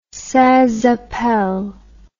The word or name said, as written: sans appel